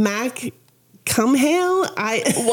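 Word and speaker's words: Mac Cumhaill: Or the Gaelic or Irish pronunciation, Mac Cumhail. – What did you just say? I'm sorry. – It's Cumhail. I'm just going to call him Finn McCool.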